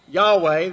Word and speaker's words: Yahweh: And scholars believe that it's best pronounced, as I've been doing it, Yahweh, that the vowels are probably an A and an E in there.